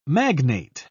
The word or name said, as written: magnate